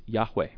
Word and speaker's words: Yahweh: And so the question is, well, how do you pronounce God's name? Some would say Jehovah, but most scholars today believe that the proper pronunciation of this YHWH is Yahweh.